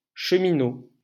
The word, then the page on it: cheminot: /ʃǝ.mi.no/